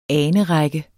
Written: anerække

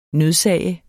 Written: nødsage

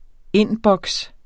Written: indboks

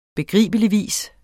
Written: begribeligvis

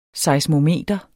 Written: seismometer

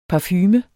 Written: parfume

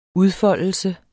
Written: udfoldelse

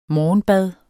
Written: morgenbad

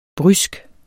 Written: brysk

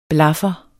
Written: blaffer

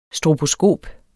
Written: STROBOSKOP

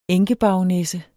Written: enkebaronesse

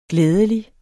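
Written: GLÆDELIGT

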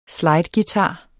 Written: slideguitar

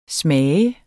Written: smager

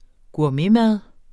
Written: gourmetmad

